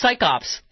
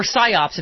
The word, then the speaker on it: psyops: It's psychops, as I've often said. Or psyops, if you're going to say it correctly.